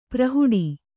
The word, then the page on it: ಪ್ರಹುಡ: Kannada; prahuḍa